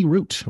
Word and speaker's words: route: Milk and honey root, or route, depending on how you want to pronounce that. – That's true. I usually say root, but... – I usually do too, but I go also say route sometimes. But Yeah, usually. – What's the... I wonder where the different pronunciations are popular, because I think root is more in the UK, right? – Yeah, I think so. – Because they talk about like having a router, not a router.